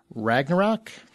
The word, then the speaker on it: Ragnarok: July 28th, 2017, Thor Ragnarok.